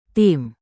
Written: tim

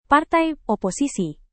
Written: partai oposisi

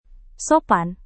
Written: sopan

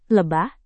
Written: lebah

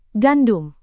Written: gandum

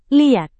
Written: liat

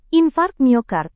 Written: infark miokard

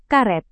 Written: karet

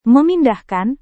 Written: memindahkan

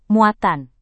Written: muatan

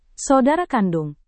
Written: saudara kandung

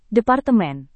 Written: departemen

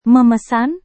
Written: memesan

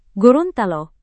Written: gorontalo

